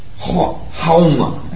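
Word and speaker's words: Haoma: But they called it the Homa sacrifice. Hauma, actually, they pronounce it.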